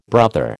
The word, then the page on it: brother: American English